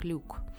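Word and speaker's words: LIWC: даже была такая идея э, сделать э, словарь, э, ну, был такой уже сделан словарь на основе английского языка, называется Linguistic Inquiry and Word Count, э, сокращенно произносится как люк.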